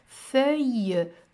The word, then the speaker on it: feuille: Que tem o E, o U e o I, três vogais juntas, não é? Feuille, feuille, o L, dois L's que não se pronuncia L, não é?